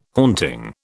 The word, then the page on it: haunting: British English